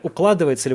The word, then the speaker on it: укладывается: Укладывается ли это в общую парадигму закручивания гаек? А, извините, так э, неправильно поставил ударение. Укладывается ли в общую парадигму закручивания гаек запрет оборота вообще под светлым знаменем борьбы с чем-либо?